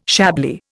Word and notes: Chablis: Indian

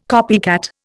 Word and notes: copycat: Indian

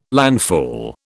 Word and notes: landfall: British English

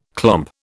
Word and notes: clomp: British English

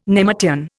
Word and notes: nemertean: Indian

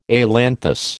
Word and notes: ailanthus: American English

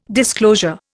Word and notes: disclosure: Indian

